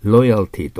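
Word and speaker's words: loyalty: Longevity で、 B も V がありますから Variety、 R は曖昧にね、で C は L ですから、しっかりとベロの先を上に当てないと、ロイヤルティという発音をしないと、ロイヤルと R の発音になると意味が違いますよ。ね、ロイヤルというのは公式、公室の、王室のという意味になってしまいますから。で、バイタリティはもちろん下唇。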